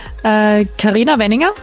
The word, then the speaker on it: Carina Wenninger: I think it was like almost okay. Carina Wenninger. – Carina Wenninger. – I like a bit of like an Austrian accent you could add, but then it's perfect.